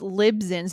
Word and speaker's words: Libsyn: And make sure that, we've mentioned several services that will host your podcast, like Podbean, Lisbon, Megaphone, that, I pronounced that wrong. It's yeah. So it's Libsyn.